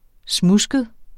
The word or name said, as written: smudsket